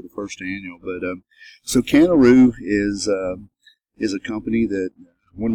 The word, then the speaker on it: CannaRoo: CannaRoo. – CannaRoo. I really like the name. Tell us a little bit about your company and CannaRoo.